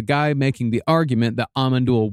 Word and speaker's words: Amon Düül: Actually, the correct pronunciation is Amon Düül.